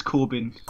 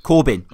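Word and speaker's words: Corbijn: I always pronounce his surname terribly because it's got like an I and a J. So it's like Corbidge, isn't it? – I think it's Corbin. – Corbin. Okay, good old Corbin.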